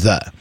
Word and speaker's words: ye: It is a P with a longer kind of top line, which means TH sound, like the th, ye old shop. So kind of like the ye was actually pronounced the.